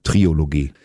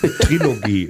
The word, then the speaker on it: Trilogie: Ich bin Klugscheißer, schreibt man mit K und der Trilogie lässt man das O weg. – Bei Klugscheißer in der Mitte schreibt man ein K? – Mhm, wer soll es dir sonst erklären, wenn nicht ich? – Okay. – Trilogie. Das ist eine schlechte Angewohnheit, Triologie zu sagen.